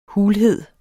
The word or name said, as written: hulhed